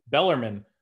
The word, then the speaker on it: Bellarmine: They play the postseason tournament, and the postseason tournament was won by a little school named Bellarmine. I don't even know if I'm pronouncing that right. – Bellarmine.